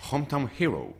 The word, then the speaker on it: Hometown Hero: Perdóname que te interrumpa, a ver cómo has pronunciado el inglés. – Hometown Hero. – ¡Ay, qué bien lo has visto potente, eh!